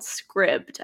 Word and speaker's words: Scribd: The one app that I do use and that I do pay for and I feel like it's totally worth it is, I think it's called Scribd, I think is how you say it. It's S-C-R-I-B-D.